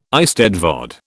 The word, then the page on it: eisteddfod: British English